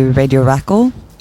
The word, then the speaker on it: Radio Orakel: In Norwegian, I'd say Radio Rakel, Radio Rakel, just like in one word.